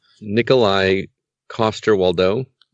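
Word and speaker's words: Nikolaj Coster-Waldau: Nikolai Coster-Waldau.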